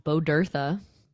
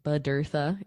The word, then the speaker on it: Bodurtha: My last name is pronounced Bodurtha. Bodurtha. It's Welsh. I'm Jewish, so just sort of a weird name passed down. And then somebody converted. So Val Bodurtha, Bodurtha, NYC, and on Instagram.